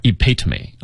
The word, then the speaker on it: epitome: 好，哎，对，我们一起来看一下啊，首先这个动词 epitomize, 它的原型是 epitome, 啊，要注意它的发音重读在第二个音节，一定不要读错，读错了你就不知道这个词什么意思了啊。